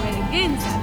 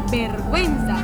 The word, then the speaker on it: vergüenza: Para indicar que se debe pronunciar la vocal U en las combinaciones G y G. A ti no te da vergüenza, te da vergüenza. Tú no vendes pinguinos, vendes pingüinos.